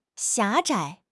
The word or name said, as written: 狭窄